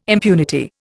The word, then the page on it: impunity: Indian